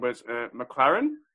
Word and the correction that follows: McLaurin: Is he the bloke with the receiver? – Terry McLaurin, yeah. – Yeah, McLaurin. He's playing some good football.